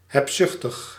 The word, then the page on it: mercenary: British English; /ˈmɜː(ɹ).sən.ə.ɹi/; /ˈmɜː(ɹ)ˌsn̩.ɹi/; /ˈmɜːrsəˌnɛri/